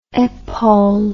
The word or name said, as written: epaule